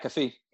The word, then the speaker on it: McAfee: Pat McAfee.